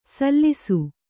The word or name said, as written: ಸಲ್ಸು